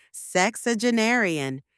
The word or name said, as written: sexagenarian